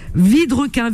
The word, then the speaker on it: Vidrequin: Et euh, par mail, vous pouvez la contacter à Vidrequin. Vidrequin, c'est son nom de famille, ça s'écrit V-I-D-R-E-Q-U-I-N. Alors, Vidrequin, V-I-D-R-E-Q-U-I-N. V-I-D et le reste comme un requin.